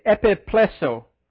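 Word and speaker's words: epiplēssō: If you go back to the word that was originally used in this verse, I'm going to make sure I say it right, it's epiplesso. That's how that word's pronounced.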